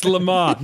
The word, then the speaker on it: Le Mans: It's Le Mans. No, it's not. I'm already giving up the S. I'm not giving up the N too. It's Le Mans.